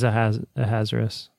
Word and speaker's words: Ahasuerus: Yeah, it is Ahasuerus.